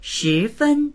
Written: shi2fen1

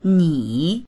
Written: ni3

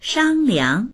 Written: shang1liang

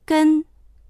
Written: gen1

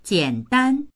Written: jian3dan1

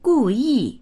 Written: gu4yi4

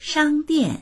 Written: shang1dian4

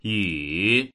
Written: yu3

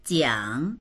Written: jiang3

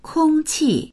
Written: kong1qi4

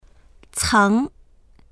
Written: ceng2

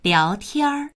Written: liao2tian1

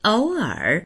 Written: ou3er3

